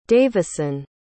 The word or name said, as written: davison